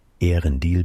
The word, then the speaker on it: Earendil: Ehrendil beginnt nicht mit einem Diphthong, hat am Wortanfang also eine Silbe zu viel.